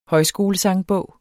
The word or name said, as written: højskolesangbog